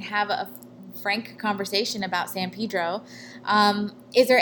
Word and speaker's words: San Pedro: San Pedro.